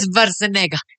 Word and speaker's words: Schwarzenegger: Pero, amiguito, mire, es Schwarzenegger. – Es Schwarzenegger. – ¿Schwarzenegger? – Pero nosotros nos enseñaron... Es Schwarzenegger. – Ah, sí. Pero entonces todos los doblajes de las películas son mal. Mal pronunciados.